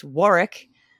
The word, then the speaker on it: Warwick: Now that I know that Warwick is pronounced Warwick, I keep randomly saying Warwick in my Robert Noonan doppelganger voice.